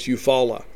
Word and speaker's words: Eufaula: It's Rodarius Thomas out of Eufaula, Alabama. I had somebody send me a pronunciation guide. I always said Eufaula. They say it's Eufaula.